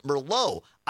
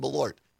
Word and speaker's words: Malort: So Malort and somebody, Julie or Jules in Mosinee, listening on WXCO, says, are you sure it's not pronounced Merlot? I actually looked it up, Jules, because I didn't know, and that's why I found that video of this guy reviewing it. And no, this is, it's spelled differently than Merlot. It's M-A-L-O-R-T, and it's pronounced Malort.